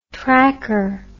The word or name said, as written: tracker